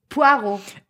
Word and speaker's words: Poirot: Og øh, der følger man hendes, øh, hendes store mesterdetektiv, Hercule Poirot. Er det ikke sådan, det udtales? – Poirot.